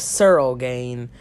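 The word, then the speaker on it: Ciryl Gane: Man, as a huge fan of, why did I say Circle Gain? I meant Searle Gain.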